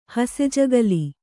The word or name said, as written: ಹಸೆಜಗಲಿ